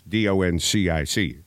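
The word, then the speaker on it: Dončić: Like Luka Doncic is Doncic. – Yeah. – But you pronounce the first C with an SH sound.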